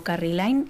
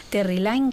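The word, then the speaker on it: Terri Lyne: Terry Lyne, a ver si lo digo bien, que a veces digo Carrie Lyne y entonces ya me he liado. Terry Lyne Carrington, que estuvo aquí hace yo creo que un par de años.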